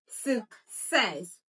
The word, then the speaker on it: success: Success. Success. The U day is giving us a schwa sound. So it's sick. Sick. Then sex. Says. So the stress is on the second syllable.